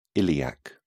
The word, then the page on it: iliac: British English